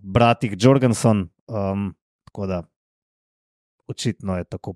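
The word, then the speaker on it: Jørgensen: In je raziskal, ne, oziroma, ne vem, on ve, kako se pravilno izgovori Jorgensen, izgovori se Jorgensen, torej kako ima že ime? – Mateo Jorgensen.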